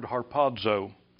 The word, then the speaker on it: harpazo: This word caught up here is, all right, when my kids get through laughing at me, caught up is the Greek word harpazo, and that's how you pronounce it.